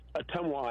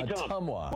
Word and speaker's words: Ottumwa: It's pronounced Ottumwa, Iowa. Hey, Ottumwa.